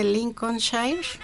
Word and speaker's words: Lincolnshire: Tiene una maestría en Administración de la Facultad en Contaduría y Administración y un MBA en Mercadotecnia Estratégica de la Universidad de Lincolnshire y Humberside.